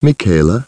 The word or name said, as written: Michaela